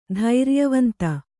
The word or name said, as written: ಧೈರ್ಯವಂತ